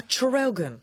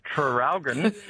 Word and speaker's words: Traralgon: Seeing as you asked about the spelling of Traralgon, could you please pronounce the first R? – Oh, Traralgon.